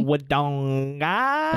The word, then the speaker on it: Wodonga: 100% will be Wodonga. – Can you say that correctly? – Wodonga. – Thank you.